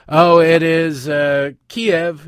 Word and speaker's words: Kiev: I'm not, it's Kiev, okay? It's just like the chicken dish. That's where it comes from, I think. I don't know, I don't care, but that's how it's always been pronounced.